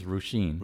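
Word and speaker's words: Róisín: How do you say Royzen? – I think it's Roisin.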